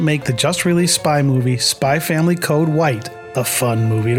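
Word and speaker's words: Spy × Family: The title of the movie is spelled Spy X Family Code White, with an X between spy and family. However, the X isn't said and the franchise is just called Spy Family.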